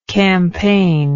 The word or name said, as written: campaigner